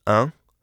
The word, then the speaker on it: un: For the sound un, pronounce the an of the English word pan, but drop the n while still keeping the vowel nasalized.